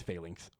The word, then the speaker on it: Phalanx: We jump forward again to year 1000, and we get to find out the answer of the, apparently it's called Phalanx, is how I've been hearing people pronounce it now, audibly, because I've been saying Phalanx. Apparently it's Phalanx.